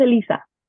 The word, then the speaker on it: Eliza: My name is pronounced Eliza.